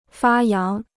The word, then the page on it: 发扬: fā yáng